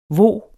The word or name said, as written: HVO